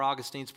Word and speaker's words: Augustine: Now, at some point, there were some needs to balance what Augustine was saying, or Augustine's pronounced both ways.